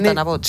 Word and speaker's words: Tanabots: Te lo he dicho bien, Tanabots. – Sí, viene de Thanatos, que es muerte en griego, y bot.